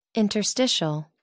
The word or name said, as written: Interstitial